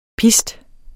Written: pist

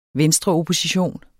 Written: venstreopposition

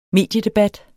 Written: mediedebat

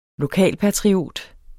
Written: LOKALPATRIOT

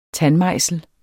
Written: tandmejsel

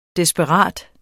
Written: desperat

